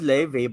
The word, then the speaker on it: Laufey: Oh, you pronounce it Leve, but it's spelled with an F? – Yeah, it's Laufey. – But it's pronounced like a V.